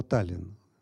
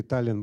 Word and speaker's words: Таллин: В каком случае Таллин был бы с двумя «н»? Если бы ударение было на последнем слоге. Таллин. Все. Мы сразу удваиваем это «н» спокойно. А Таллин «н» а съедается последний, откусывается кусочек. Невозможно.